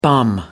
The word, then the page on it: bum: American English